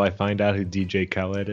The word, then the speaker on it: DJ Khaled: And then the other day, back when I was a free Twitter user, I posted a joke about DJ Khaled. Is that how you say it? – Oh, yeah.